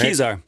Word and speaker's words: Kezar: Kezar. Spelled K-E-Z-A-R, but you say it as in, I don't know where my keys are.